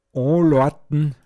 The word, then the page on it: de Loata å(n)loan: Pinzgauer Mundart